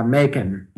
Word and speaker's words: Macon: Yes, South Georgia, Macon. – Which rhymes with bacon.